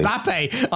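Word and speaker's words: Mbappé: Let me say that properly. Mbappe.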